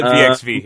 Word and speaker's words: WXW: We call it VXV here, by the way. – VXV. – That's what proper pronunciations only. Proper German pronunciations.